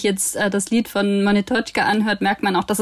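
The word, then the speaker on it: Monetotschka (Монеточка): Wie war das zum Beispiel mit Monetorga? – Wie spricht man das äh, richtig aus? Monetotschka. Monetotschka, okay.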